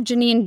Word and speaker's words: Jenine: And my name is spelled Janine, J-E-N-I-N-E, because nobody ever spells it right.